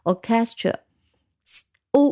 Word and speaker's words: orchestra: Orchestra，O R 第一个音节，O K，这里开始 C H 啊，发成 K，所以是不规则的读音，C H E，stra，S T R A，Orchestra 是交响乐队，那么普通的。